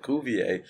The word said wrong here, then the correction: Cuvée: Oh. And Matt went, Cuvée?